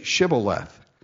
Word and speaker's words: Shibboleth: Say Shibboleth. And, of course, he could not frame to pronounce that right. The Ephraimites had a problem with their speech. They couldn't say the sh. They had to say s.